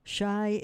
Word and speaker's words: Chi: Likewise, Chicago is shortened to Chi, which I have heard from a listener, is correct. Chi is the pronunciation of C-H-I.